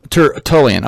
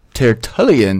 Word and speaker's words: Tertullian: Tertullian.